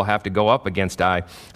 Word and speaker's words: Ai: Unbeknownst to Joshua, in verse 2, now Joshua sent men from Jericho to Ai, or to Ai, actually, is how it is pronounced, which is near Beth-Aven to the east of Bethel, and told them, go up and spy out the region.